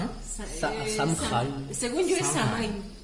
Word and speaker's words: Samhain: según yo es Samhain. – Samhain. Bueno, es celta. Es celta. – Mm, puede ser que sí sea una pronunciación correcta.